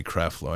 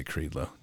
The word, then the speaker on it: Creflo: Yeah, it's probably Creflo. I always say Creedlo. Don't worry about it. It doesn't deserve the right pronunciation anyway.